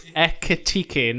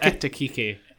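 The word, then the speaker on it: Ekitiké: Ekitike aside, a more detailed look at the attacking alternatives. – Ekitike. You did this last week. – What did I say? Ekitike. Ekitike. – Yeah. It's Ekitike, not Ekitike.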